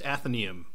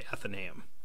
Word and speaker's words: Athenaeum: So it's pronounced Athenaeum. That's the correct way. You can also say Athenaeum.